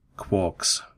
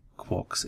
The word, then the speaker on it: Quark: Neutron zu bilden, so wie in dem Text ja auch drei Quarks erwähnt werden. Das Wort muss übrigens nicht über den Englisch Quark ausgesprochen werden, sondern kann ruhig auch auf Deutsch Quark verwendet werden,